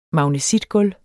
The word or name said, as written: magnesitgulv